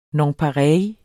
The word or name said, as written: nonpareille